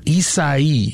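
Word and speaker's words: Isaias: Is that how you say it? Isaia? – Isaia. – Isaia. – Yeah.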